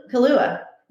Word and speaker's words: Kalua: You've got the lunch plate kulau. No, that's pork. Pronounce it for me. Kalua. – Kalua. – Yeah, straight up Kalua.